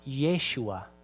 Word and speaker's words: Yeshua: In Hebrew, it's pronounced Yeshua, and it means Yahweh saves.